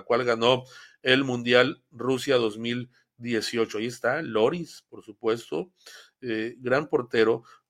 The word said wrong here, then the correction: Lloris: Eh, ah, pues con el Tottenham Producer, el Tottenham en, en Europa en la liga Lloris, perdón, se pronuncia Lloris.